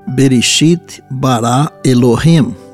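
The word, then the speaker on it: Bereshit bara Elohim (בראשית ברא אלהים): Em hebraico, a expressão no princípio criou Deus se pronuncia assim, Bereshit bara Elohim.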